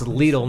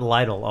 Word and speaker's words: Lidl: It's Lidl, not Lidl, also, by the way, for folks who don't know what the grocery store is called.